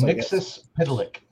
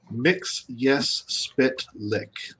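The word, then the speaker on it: Mxyzptlk: I don't know how to pronounce his name. Nobody does. – Mixus Pitlick. – Mix, yes, spit, lick. When Gilbert Gottfried showed up in the Superman animated series, he broke it down.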